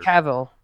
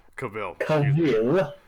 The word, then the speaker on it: Cavill: I think at this point, the coincidence of the news coming out at the same time as Henry Cavill or Kimball, I can't even say his name now either. – Cavill. It's Henry Cavill. – Cavill.